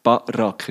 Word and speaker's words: Barack: Gibt's schwierig bei vielen Namen, aber jetzt Barack ist auf der zweiten Silbe.